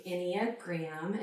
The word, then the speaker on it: Enneagram: And you said it correctly, Enneagram.